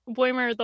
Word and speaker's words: Boimler: And I kind of like that Boimler the whole time, or Bulmer, I keep pronouncing it wrong.